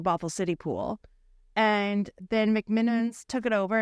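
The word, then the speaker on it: McMenamins: I say McMiniman's wrong. Did I say it right that time? – You did that time.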